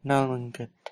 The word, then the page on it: nalwenket: Lwaitel